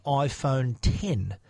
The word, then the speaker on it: iPhone X: Now it's when you look at the name of this phone, it says iPhone X, but X being the Roman numeral for 10, Apple were pronouncing it 10 on the stage. I keep catching myself calling it X, calling it 10. So it is the 10 is Apple's official name for it.